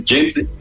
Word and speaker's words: Jensen: E com o catfish, eles trouxeram alguns técnicos americanos, entre eles o doutor John Jensen, e é assim a pronúncia mesmo, né? Nós, alemães, colocaríamos um Jensen, mas é Jensen.